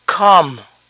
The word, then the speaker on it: calm: Calmado, calm, calm, la L no se pronuncia, calm, palm.